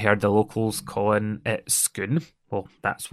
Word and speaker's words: Scone: I kept calling it Scone, thinking that's what the village was called because that's how it's spelt.